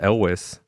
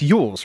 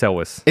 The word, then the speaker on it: Elwes: So when we saw that Carrie Elwes, pronounced Elwes. – Is that actually? It's Yules, right? – No, it's Elwes.